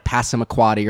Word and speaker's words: Passamaquoddy: It's like Passamaquoddy or something like that.